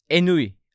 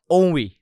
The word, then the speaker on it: ennui: Bu bölüm ennui, ennui diye okunuyor. Fransızca bir kelimeymiş.